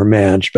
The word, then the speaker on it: Maj: I was saying it wrong before Madge, but it's Madge.